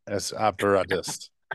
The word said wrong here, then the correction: ex opere operantis: I think you're not pronouncing it right, but it's okay. – Oh yes, you're right on. Ex opere operantis.